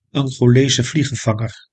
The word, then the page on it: Angolese vliegenvanger: An·go·le·se vlie·gen·van·ger